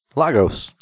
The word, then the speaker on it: Lagos: So it's actually in, I don't know if I'm pronouncing it right, but Lagos, Portugal? – Yeah, Lagos. – Lagos. Sure.